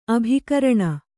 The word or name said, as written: ಅಭಿಕರಣ